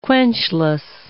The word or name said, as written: quenchless